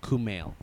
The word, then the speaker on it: Kumail: No, K-A-M-I-L. – K-A-M-I-L? – Yeah. Oh, no. K-U-M-A-I-L. Sorry. – I knew there was K-U- a U in there. – Kumail. – K-U-M-A-I-L.